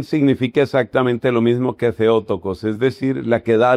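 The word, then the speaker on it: Theotokos: Algunas personas pronuncian Teótocos. Es Teótocos, la palabra Teótocos que empieza con TH, o Teótocos pronunciemos, significa la que da luz a Dios.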